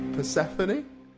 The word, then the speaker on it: Persephone: So his mum's called. Persephone. Is that right? Yep. Is that how you pronounce it? Yeah, that's right.